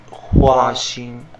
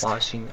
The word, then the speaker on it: Huaxin: So it's pronounced Huaxing. – Huaxing? Yeah. – Okay, nice. – Huaxing, H-U-A-X-I-N.